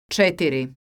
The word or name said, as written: cetiri